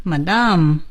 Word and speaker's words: Madame: Yes, I hope you know this person, Madame Curie. – OK, 我们说今天的课文很好，但是我们在学课文之前先看一下单词。 – New words, new page. – 我们从夫人这个词开始。 – Madam. – Madam. It sounds French, right? – Yes. – Madam, 这是英语。法国人怎么读呢？ Madame. Madame, that's French.